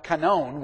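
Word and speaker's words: kanon (κανών): Now, the word canon is actually a Greek word pronounced kanon. We just call it canon.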